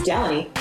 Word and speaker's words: Delaney: My name is Delaney, rhymes with Melanie, West.